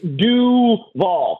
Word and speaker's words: Duval: Like, I mean, if you know anything about anything, don't you know it's Duval, right? That it's this, how do you emphasize the wrong syllable of that when you're the head coach there?